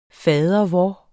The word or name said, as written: fadervor